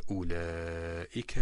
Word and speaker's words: أولئك: ويدرؤون بالحسنة السيئة أولئك لهم عقبى الدار. هنا كذلك في كلمة أولئك وضعت دائرة تامة على الواو، يعني أن هذه الواو تهمل إهمال مطلق ليس لها حظ في النطق إطلاقا. وأود أن أسأل أعزتي المستمعين في كلمة ويدرؤون، ما نوع المد في كلمة ويدرؤون؟